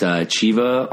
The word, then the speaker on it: Thiva: It's spelled T-H-I-V-A, so a lot of people say Thiva. He's from Brazil. He's Portuguese. – Who's the singer? – Chiba. So it's Chiva on guitar and vocals, Max on bass, and of course yours truly on drums.